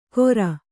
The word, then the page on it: ಕೊರ: kora